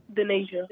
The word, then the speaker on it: Dhaneja: D-H-A-N-E-J-A. – Hi, honey. Tell us how to say your name. – Dehaneja.